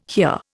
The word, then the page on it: hear: Indian